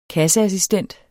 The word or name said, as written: Kasseassistent